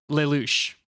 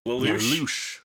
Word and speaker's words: Lelouch: Originally, how you pronounce that word? – Lelouch. – Lelouch. – Lelouch. There's a lot of French and, you know, East Western European influence here.